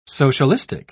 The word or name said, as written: socialistic